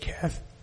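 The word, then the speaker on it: Cheth: And that Hebrew letter is, well, you could say it like this. It's C-H-E-T-H, Keth.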